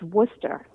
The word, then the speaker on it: Worcester: It's pronounced Worcester. – Oh, okay. – But yes, Worcester, Mass. Or Worcester, if you really want to say it with an accent.